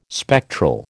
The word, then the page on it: spectral: American English